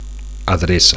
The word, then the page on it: adreca: Catalan